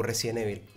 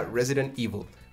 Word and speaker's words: Resident Evil: Resident Evil, bueno, mal pronunciado ahí, eh, se pronuncia Resident Evil.